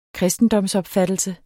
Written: kristendomsopfattelse

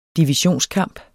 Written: divisionskamp

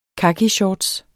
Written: kakishorts

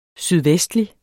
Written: sydvestlig

